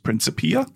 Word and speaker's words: Principia: is it pronounced Principia? – Correct.